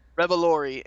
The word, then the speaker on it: Revolori: Revolori and Laura Harrier cast as Peter's classmates.